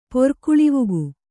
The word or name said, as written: ಪೊರ್ಕುಳಿವಕ್ಕಿ